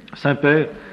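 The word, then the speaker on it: semper: Ecclesia reformata, Saint-Père, c'est pas comme le Saint-Père, hein, ça n'a rien à voir. S-E-M-P-E-R. S-E-M-P-E-R. Reformanda, comme ça se prononce.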